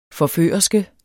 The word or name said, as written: forførerske